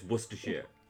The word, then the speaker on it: Worcestershire: No, you were fine. You were fine. Don't get put off by all of that craziness in the middle. It's just Worcestershire.